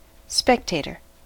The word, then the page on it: spectator: American English